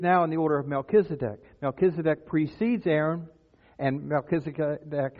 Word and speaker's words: Melchizedek: He became a high priest forever in the order of, have fun with the name, Melchizedek is the way I pronounce it. I wasn't there when he got named, so I don't know how the official pronunciation is, but I say Melchizedek.